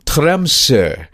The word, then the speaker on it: Tromsø: It's about 72 degrees north latitude, and we see the word, and we call it Tromsø, but in Norwegian, the correct pronunciation is Tromsø.